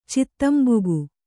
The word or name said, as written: ಚಿತ್ತಂಬುಗು